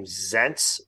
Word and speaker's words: Zients: Being replaced, by the way, who was his, I believe you pronounce his name, Zients, Z-I-E-N-T-S.